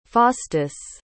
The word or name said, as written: faustus